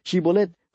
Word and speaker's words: Shibolet: Shibolet fue una palabra difícil de pronunciar para estos efrainitas. No podían pronunciarla con la H.